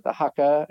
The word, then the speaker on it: haka: And so coming into the end, having that, I didn't know they did the haka, I think is how you say it.